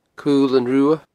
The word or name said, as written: Cul an Rubha